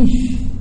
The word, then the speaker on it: Ish: Mas em vez de Ish, eu pronunciar Esh, eu estou pronunciando fogo.